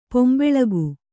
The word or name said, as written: ಪೊಂಬೆಳಗು